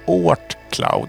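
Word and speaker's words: Oort Cloud: Eh, låten heter Tranflykt och eh, artisten är Ort Cloud. Jag tror jag uttalar det. O-O-R-T Cloud och då är det som i Soundcloud fast man tar bort sound och ersätter med ort.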